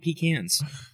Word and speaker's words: pecan: No, the actual pronunciation is pecan. A pecan is something you take out into the woods, a little can, and you go to the bathroom in. That's a pecan.